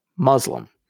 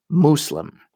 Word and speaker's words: Muslim: Throughout this podcast, I have said the word Muslim. This is actually an incorrect pronunciation. But the correct pronunciation just sounds forced and almost snobbish from a Christian English speaker. So I will continue to say it wrong. If you want to pronounce Muslim as it appears in the Arabic, it should be Muslim. It should start with the sound of moose, not muzzle. The most important part is using the S and not a Z.